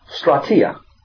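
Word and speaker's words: strateia (στρατιά): In Greek, it's pronounced strateia. We know it from strategy, strategic. Remember Elijah and his servant,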